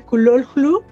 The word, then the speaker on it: Cthulhu: Em, entonces, claro, respeto mucho a Lovecraft, eh, respeto mucho a los Lovecraftianos, me encanta que eh, Cthulhu, que en realidad debería pronunciarse Kulolhu, según, según vi por allá que Lovecraft le había escrito conmigo que la pronunciación correcta era esa, casi nadie lo sabe.